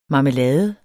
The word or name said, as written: marmelade